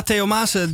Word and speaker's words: Theo Maassen: En eh, Theo Maassen, zeg ik dat goed? – Ja, Theo Maassen, de uh, Brabantse cabaretier, die speelt binnenkort in Theater de Liefde in Haarlem.